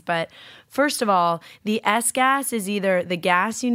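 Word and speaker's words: Sgass: The wine is 2017 Sauro Mall, the S-Gas, that's what it's called, and it's spelled S-G-A-S-S, S-Gas, and it's from the Veneto in Italy.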